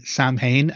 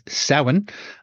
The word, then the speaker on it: Samhain: Now, those are the pronunciations of those words. It's not ever and never has been Samhain and never will be. It is Samhain. Also, it's never Beltane. It's Bealtaine. So those are the words.